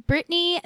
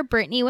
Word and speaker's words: Brittani: You can also find me on Instagram as Brittany the Ginger. Brittany with an I, the Ginger.